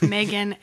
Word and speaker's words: Meghann: It's Meghann, M-E-G-H-A-N-N.